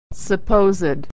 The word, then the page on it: supposed: ed